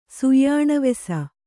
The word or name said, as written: ಸುಯ್ಯಾಣವೆಸ